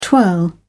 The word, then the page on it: twirl: British English